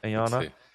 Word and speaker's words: IONNA: IONNA? – Yeah. Yeah. – IONNA.